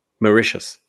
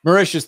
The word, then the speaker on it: Mauritius: Mauritius. – Mauritius.